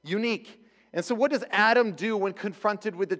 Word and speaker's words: Adam: Adam is how it's pronounced in Hebrew, and it means man, by the way. Very creative name. And so Adam, he was the one who God, you know, took from the dust to the ground, breathed the breath of life into him.